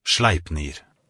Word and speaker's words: Sleipnir: Sleipnir? – Sleipnir.